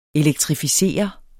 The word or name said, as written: elektrificere